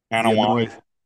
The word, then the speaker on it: Anoa'i: Anoa'i. – Anoa'i, yeah.